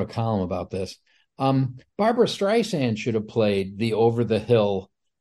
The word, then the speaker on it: Streisand: I don't see that movie getting made because I don't see Barbara Streisand agreeing to that at that time. – Because John Peters was busy teaching people how to say her name. – Streisand.